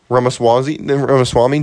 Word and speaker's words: Ramaswamy: I am so afraid to butcher this. – Vivek Ramaswamy? – Yeah. Ramaswamy? Dude, this guy is fantastic.